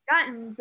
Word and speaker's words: Guyton: I'm probably saying her last name wrong. – Guttons. – That's how you say it.